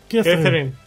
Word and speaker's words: Catherine: Кэтрин.